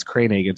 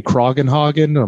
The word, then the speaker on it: Kranhagen: But it's Kranhagen. So I shortened it because my whole life, teachers mispronounce it. Everybody messes up my last name. So when I started the first 2 weeks in a stand-up, a host was like, Anthony Kroggenhagen. I'm like, oh my God, this guy sucks.